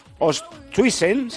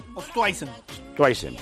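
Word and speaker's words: Oosthuizen: Líderes en la penúltima jornada, Ostuisen, se dice así. – Ostuisen, pues ese.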